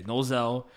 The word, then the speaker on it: Nozel: a young Nozel. That's how you say it. Yeah, Nozel.